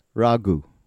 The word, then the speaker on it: Raghu: Raghu. – Raghu. – Yeah, just the emphasis on the ra.